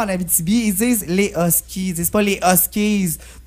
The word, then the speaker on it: Huskies: Ça s'écrit Huskies, mais ça se dit Huskies. – Ouais, ça se dit Huskies. C'est un S silencieux.